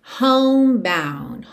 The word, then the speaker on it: homebound: And I'm, como eu já expliquei, ligação no N, homebound. Homebound. Aqui, atenção que o E de home não é pronunciado. O D de bound poderia aparecer ou não, ok?